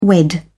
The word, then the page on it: unwed: British English